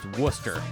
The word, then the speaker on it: Worcester: And it's actually pronounced Worcester. – And Worcester. – I've heard of Worcester, Massachusetts. – Yeah. – But I didn't realize that's what it was. Yeah, we got a shout out. Tulsa got a shout out. Shout out, Tulsa. We're also a thing. We'll get there. Here we go. – Yeah! – First one on flyover towns. But yeah, it's like Worcester is how it's pronounced.